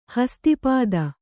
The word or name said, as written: ಹಸ್ತಿಪಾದ